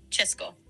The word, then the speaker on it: Česko: Si quieres referirte al país de forma corta, como suelen hacerlo hoy en día, dicen Česko, que se pronuncia Česko.